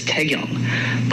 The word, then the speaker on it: Taegyeong: Which is Taegyeong. But I can't reasonably expect people to pronounce it because for non-native Korean speakers, like the vowel sounds are literally different.